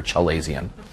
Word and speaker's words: chalazion: I've mispronounced it for a long time, so I'm trying to remember Chalazion.